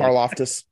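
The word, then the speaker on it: Karlaftis: Karloftis.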